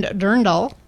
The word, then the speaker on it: Dirndl: Live music by A-Town, food trucks, and they even have a costume contest for the best Lederhosen and Dirndl. Did I say it right? – Yep, you did.